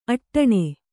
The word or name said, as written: ಅಟ್ಟಣೆ